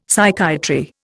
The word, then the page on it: Psychiatry: Indian